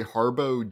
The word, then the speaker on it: Harbo: His name is Gregory Harbo Jr. Harbo spelled like Harbaugh, not related to those Harbaughs.